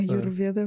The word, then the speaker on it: Аюрведа: Вот, и она с помощью вот этой вот э, Аюведы, Э, аюрведа вроде по-русски.